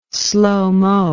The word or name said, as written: slomo